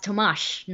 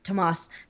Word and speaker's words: Tomáš: Oh, and by the way, his name is pronounced Tomáš, not Tomas.